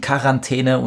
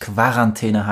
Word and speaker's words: Quarantäne: Das Wort entstand in Italien als Quarantäne, dann kam es nach Frankreich, wo es zu Quarantäne wurde und die Deutschen haben bei Fremdwörtern die K-Aussprache übernommen.